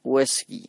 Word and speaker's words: Uesugi: Bueno, la palabra Uesugi que estoy diciendo todo el rato, Uesugi, en realidad en japonés se pronuncia Uesugi o algo así, ¿no? Porque la us, sobre todo después de una S, se pronuncia muy poco.